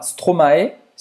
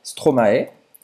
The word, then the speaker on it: Stromae: que se chama Stromae, Stromae.